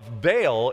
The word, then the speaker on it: Baal: And when you see the name Baal, B-A-A-L in the Bible, it's pronounced Baal in their language.